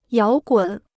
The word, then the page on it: 摇滚: yáo gǔn